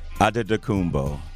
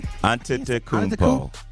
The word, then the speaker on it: Antetokounmpo: Giannis, and how do you say it? – Antetokounmpo. – Antetokounmpo. Antetokounmpo. – Yes.